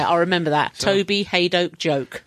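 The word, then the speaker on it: Hadoke: Rhymes with joke. Haydoke. That's how I remember it.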